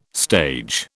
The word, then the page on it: stage: British English